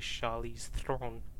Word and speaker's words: Charlize Theron: If you want to say her name, if you want to say her name properly, Charlize Theron.